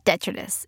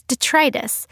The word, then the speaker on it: detritus: But it's actually not detritus. It's detritus.